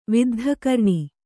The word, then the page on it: ವಿದ್ಧಕರ್ಣಿ: viddha karṇi